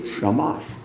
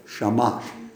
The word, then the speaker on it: shamash: And I'm pronouncing that right, by the way, shamash, because I went online.